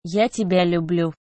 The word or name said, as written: ya tebya lyublyu